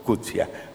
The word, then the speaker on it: Coetzee: Y estaba, por supuesto, Coetzee, como dicen que se pronuncia en africano.